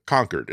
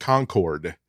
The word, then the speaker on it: Concord: You, you, pronounce it Concord. It's the name name of name of a town. – Yeah, uh, in in Massachusetts, and Matt said Concord.